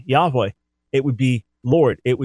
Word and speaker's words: Yahweh: I believe Yahweh is our best understanding. I reject the idea that it was originally Yehovah or Yehovah, but put that aside.